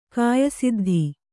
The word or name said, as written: ಕಾಯಸಿದ್ಧಿ